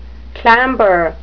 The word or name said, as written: clamber